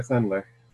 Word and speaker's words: Sender: Am I saying the name right? I've been in the house too long. Say? – Yeah, Rabbi Sender. – Rabbi Sender. Good.